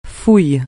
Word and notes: fouille: francais

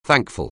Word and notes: thankful: anglais

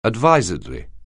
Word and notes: advisedly: anglais

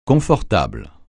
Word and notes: confortable: francais

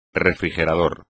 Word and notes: refrigerador: espagnol

